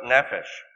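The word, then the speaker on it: nefesh (נפש): The Hebrew word behind it is nefesh, the word nefesh. – 犹太话里面这个字怎么念的呢？就是 nefesh.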